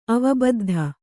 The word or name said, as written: ಅವಬದ್ಧ